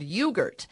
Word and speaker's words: Ewegurt: I am back with Jennifer Erdman, who I have just met because of this quite amazing product she has called Ewegurt. I love the name. It's spelled E-W-E as in the female sheep, gurt as in yogurt.